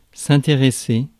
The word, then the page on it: interest: /ˈɪn.tɹɪst/; American English; [ˈɪn.tɹɪst]; /ˈɪntɹɛst/